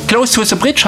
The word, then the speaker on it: Close to the Bridge: Close to the Bridge, habe ich hoffentlich richtig ausgesprochen? – Auf jeden Fall.